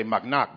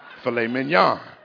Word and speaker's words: filet mignon: Back in the hood, I used to say mock knock, but now I know how to say the word filet mignon.